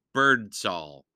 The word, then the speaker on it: Birdsall: and it's called The Penderwicks by Jeanne Birdsall, and that is spelled B-I-R-D-S, like birds, then A-L-L, Jeanne Birdsall. Not birds some, not birds little, all the birds.